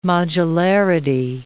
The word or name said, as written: modularity